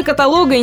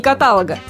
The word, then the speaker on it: каталог: Да, именно каталога и никак не каталога. А это момент принципиальный. Что интересно, как не меняй слово, ударение сохранится на третьем слоге.